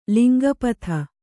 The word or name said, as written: ಲಿಂಗಪಥ